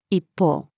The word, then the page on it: 一方: female